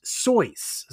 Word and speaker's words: Seuss: In a pinch, he altered the pen name to Seuss, here name pronounced Soyce.